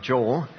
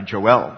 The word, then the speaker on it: Joel: But one of the guys in our church who's unable to come because of physical issues here for several weeks, months, he goes by Joel because he wants the Hebrew pronunciation. So I might switch between Joel and Joel as we go through this, but that's how the English Hebrew pronunciation is, Joel.